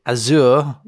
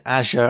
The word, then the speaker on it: Azure: I said Azure last time. It's Azure. I mispronounced it. Azure.